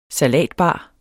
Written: salatbar

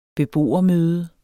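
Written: BEBOERMØDER

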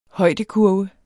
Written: højdekurve

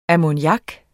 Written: ammoniak

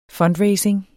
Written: fundraising

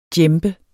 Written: djembe

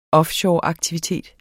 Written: offshoreaktivitet

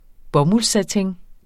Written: bomuldssatin

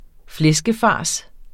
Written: flæskefars

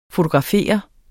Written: FOTOGRAFER

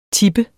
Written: tipper

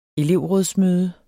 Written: elevrådsmøde

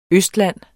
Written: østland

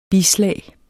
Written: bislag

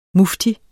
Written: mufti

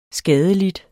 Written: skadelidt

